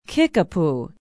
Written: kickapoo

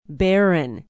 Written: Barron

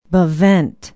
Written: bevent

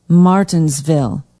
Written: martinsville

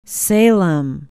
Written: Salem

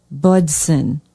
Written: budsin

